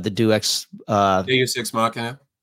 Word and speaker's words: Deus ex machina: What besides the and forgive me because I can never pronounce the Deus Ex Machina.